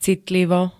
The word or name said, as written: citlivo